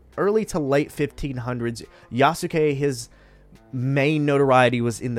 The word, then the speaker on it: Yasuke: His name is Yasuke. Now, I looked up that pronunciation to make sure I got it right, because it's important.